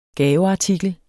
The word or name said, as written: gaveartikel